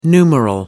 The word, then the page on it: numeral: American English